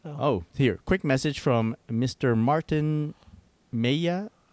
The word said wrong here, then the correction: Mejia: I'm going to mispronounce this. Mejia. Right. M-E-J-I-A.